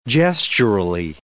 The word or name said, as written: gesturally